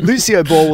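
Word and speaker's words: Lúcioball: So Lucio Ball. Did I say that right again? – Yes, that's okay. Cool. – Lucio Ball will introduce Sydney, Australia as a new map environment.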